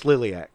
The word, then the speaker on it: Liliac: Is it pronounced Liliac or Lilac? – It's Liliac. It is Liliac.